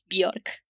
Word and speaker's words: Björk: a Islandia una cantante que conoceréis, espero que sí, y cuyo nombre es un poco impronunciable, pero es Björk, ¿vale? Björk.